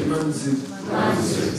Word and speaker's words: Manju: Manju. – So the Sanskrit J, Sanskrit doesn't have a J. It's Z. Manju. Manju Gosha. Manju Shri. Okay? All that Manju Shri is mispronounced.